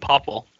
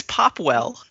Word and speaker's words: Popwell: Well, you're standing taller than Popple. – I like how you're not pronouncing the W. It just makes it even better. – Is it Popwell? – It's Popwell.